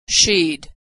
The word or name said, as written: she'd